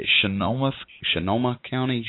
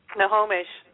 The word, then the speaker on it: Snohomish: He's in, and tell me if I pronounce this right, Sonoma County. It's a... – Snohomish.